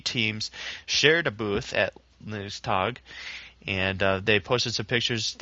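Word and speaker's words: LinuxTag: Linux Tag, okay. – I can't remember it. – Here it's Linux. We use the Linux pronunciation, but Tag, of course, is the German word for day.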